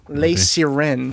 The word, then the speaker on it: Les Sirènes: Oh, well, it's sirens, but Les Sirènes. – Les Sirènes. – Yeah. – Okay. He lost his eyesight during that. – It's like Les Miserables.